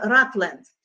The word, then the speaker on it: Rutland: Вот, сейчас оно называется Ratland. Но вы, кто знает английский, в английском буква «У» читается как «А». То есть если мы читаем без транскрипции, то это Rutland.